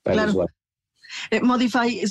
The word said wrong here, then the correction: Mudafy: se escribe M-U-D-I. F. – es Mudafy y es M-U-D-A-F-Y.